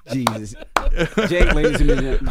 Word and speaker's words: Cenk: Cenk is tough spelling. It's C-E-N-K, right? It's a Turkish name. The C is pronounced like a J.